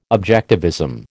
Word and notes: objectivism: American English

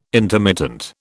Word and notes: intermittent: British English

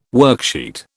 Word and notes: worksheet: British English

